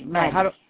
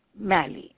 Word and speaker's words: Mali: M-A-L-I, Mali. – How do I say it? Mali, you said? – Mali, yeah.